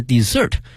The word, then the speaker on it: dessert: 那么还有一个词呢 d-e-s-s-e-r-t 多了一个 s，它的发音是 desert，它是指呢我们去点快餐的时候呢会有一些小的甜品，比如说像奶昔啊冰激凌啊等等，那么它通称为 dessert 啊，甜品。